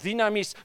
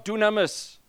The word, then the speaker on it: dynamis: the power of God is pronounced dynamis, not dunamis. And I was strong on that. You pronounce your Greek right. God could care less. If someone's moving in dunamis power, and someone here can pronounce dynamis, but they're not moving in it, he'd rather have this guy over here.